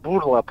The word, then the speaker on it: Бурла: Просто гендиректор э, компании Pfizer, его зовут, по-моему, господин Бурла, э, Бурла, правильное ударение,